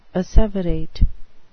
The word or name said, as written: asseverate